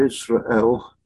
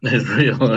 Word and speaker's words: Israel: Israel, okay. I mean, I've learned to try and say it as three syllables, Israel, rather than Israel, as though it's only two.